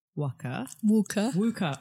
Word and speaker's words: Wukaware: So for the listeners who don't know, Ruby has created Wukaware.